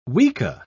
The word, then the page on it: weka: British English